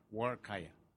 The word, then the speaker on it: Huarcaya: Well, the H is silent. – Oh. How do I say it? Say it correctly. – So it's like saying war, like going to war with a kayak. Huarcaya.